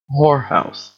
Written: whorehouse

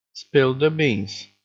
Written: spill the beans

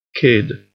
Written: kid

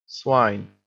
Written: swine

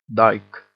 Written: dyke

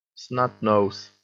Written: snot-nose

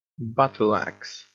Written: battle-axe

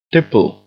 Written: tipple

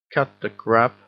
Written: cut the crap

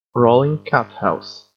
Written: rolling cat house

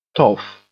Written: toff

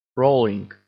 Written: rolling

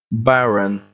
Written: barren

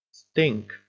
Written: stink

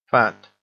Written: fat

